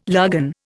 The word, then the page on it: lagan: Indian